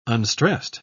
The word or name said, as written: unstressed